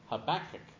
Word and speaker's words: Habakkuk: Habakkuk. – Say it out loud. – Habakkuk. – Habakkuk? Any others? You're close. If you were Hebrew, you'd say Habakkuk.